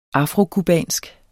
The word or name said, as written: afrocubansk